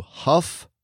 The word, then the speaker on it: Hough: We got to give a shout out to Huff, Ohio. H-O-U-G-H. It's pronounced Huff.